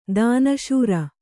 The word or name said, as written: ಧನಾಶ್ರೀ